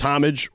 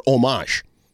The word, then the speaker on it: homage: It depends if you speak in Francais or if you speak in English. It's homage or homage.